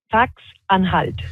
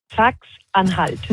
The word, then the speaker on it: Sachsen-Anhalt: Sachsen-Anhalt. – Alors, plus sérieusement, ce qui retient vraiment l'attention, c'est cette victoire de l'extrême droite en... – Sachsen-Anhalt.